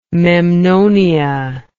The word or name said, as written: memnonia